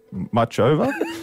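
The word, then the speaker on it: Muchová: Ash Barty is on court this morning against 25th seed Caroline Muchova. Is that how you say it? – Yeah, that'll do.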